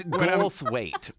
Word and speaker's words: Goldthwait: It's Goldthwait, right? – Goldthwait. But it's okay. People mispronounce it all the time.